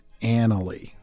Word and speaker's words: Annaly: you should just note that the stress is on the first syllable, but it takes a short vowel sound, like the name Ann, Annaly, okay?